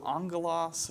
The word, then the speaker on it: angelos: and take the two G's in Greek have a N-G sound. So angelos, or angel, right, message.